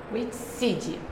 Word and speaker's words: Witseed: E hoje a gente vai receber que é da WITSET, que eu não posso falar errado, WITSET. – WITSEED. – WITSEED. Ah, foi quase. Eu, te, eu tenho esse problema com a pronúncia do E do inglês.